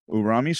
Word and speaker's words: Urami: And moving up to number two this week, Tomb of Urimi, if I'm saying that one right, Urami. – Urami, so it's spelled U-R-A-M-I, and it's a rare from Saviors of Kamigawa.